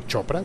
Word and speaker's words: Chopra: Están preparando, ya supimos, están preparando en Snapchat un nuevo equipo humano eh, al mando de Raúl Chopra, si no estoy mal, se pronuncia Chopra como el famoso escritor de autoayuda, sí.